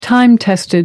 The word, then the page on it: time-tested: American English